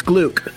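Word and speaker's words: Glück: I had to, I had to Google for, I'm going to say it wrong because I can't remember how it was pronounced. Gluck. It's Gluck.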